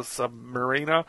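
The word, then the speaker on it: Sub-Mariner: And he has wings on his ankles, like the Submariner, or Submariner, depending on how you want to say it. Yeah, but when he turns back... – It's pronounced the Sub Mariner.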